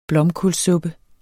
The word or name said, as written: blomkålssuppe